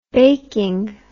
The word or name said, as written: baking